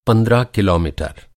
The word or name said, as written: किलोमीटर